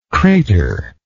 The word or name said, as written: craterlike